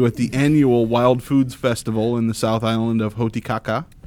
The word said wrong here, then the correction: Hokitika: Hotikaka. – I'm sorry, Hokitata. – It's coming. – Hokitika. God damn.